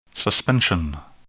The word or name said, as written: suspension